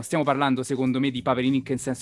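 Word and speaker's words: Paperinik: Allora aspetta, aspetta, io chiesi a Valentina De Poli, allora Paperinic si pronuncia Paperinic. Ok.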